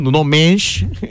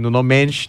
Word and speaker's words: Nuno Mendes: Doğru bir telaffuz yapalım. Ama ülkemizde tabii Mendes de denilince de çok daha doğru oluyor. Nuno Mendes. Nuno Mendes diyelim belki. – Nuno Mendes. – Kendisi de bizi dinliyorsa doğrular. Telaffuz doğru mu Selim Hoca? Nuno Mendes. Nuno Mendes. Yaptık. Ya belki de doğrular. – Doğru bence. – Doğrudur herhalde. – Ben onaylıyorum.